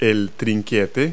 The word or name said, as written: ratchet